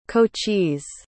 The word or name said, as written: cochise